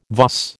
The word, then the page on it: vas: American English